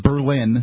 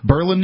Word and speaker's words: Berlin: That's Berlin, spelled like Berlin. – Right. – I don't pronounce it that way here. Berlin, New Hampshire.